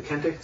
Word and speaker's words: Kente: Oh, by the way, I want to start with, if you know, I think it's pronounced as Kente, the Kente scarf.